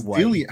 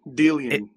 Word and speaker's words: Dillian: No, it's Dillian. How hard is this like Dillian? It's just Dillian Whyte. – It's as like hard. – Dillian. Dillian.